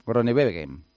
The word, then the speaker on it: Groenewegen: Groenewegen. – Groenewegen.